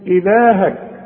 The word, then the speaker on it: إلهك: إلهك اللام مرسوم عليها ألف علشان تمدها تقول إلهك